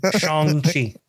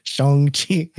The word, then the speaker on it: Shang-Chi: Shang-Chi. – Shang-Chi.